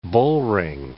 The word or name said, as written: bullring